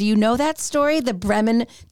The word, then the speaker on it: Bremen: I'm not even sure if we say that correctly. B-R-E-M-E-N. Bremen.